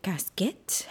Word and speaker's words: casquette: I was like, is it casquette?